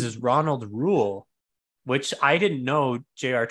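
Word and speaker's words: Reuel: you did misspell, but it's Rule. Close enough. – How yeah, do you spell it? It's an H? – It's R-E-U-E-L.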